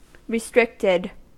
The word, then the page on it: restricted: American English